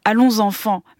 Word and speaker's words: Allons enfants: D'abord, je vais le prononcer à la française parce que ça vient de la Marseillaise quand même. Allons enfants, mais qui s'écrit Allons Safan, des frères Taviani, dont on vient d'entendre un extrait.